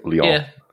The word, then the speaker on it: Lyon: It's L-Y-O-N. Is that Lyon? – I think it's Lyon. – I think it's Lyon. – Yeah. Lyon. Okay.